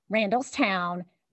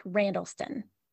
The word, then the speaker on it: Randallstown: Randallstown is pronounced Randallstown, not Randallston.